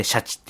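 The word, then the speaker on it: SHACHI: あの、フランス語で D は読みません、発音しませんみたいな、そういう感じのその、チームシャチと書いて、チームは発音せずにシャチって読ませるっていう、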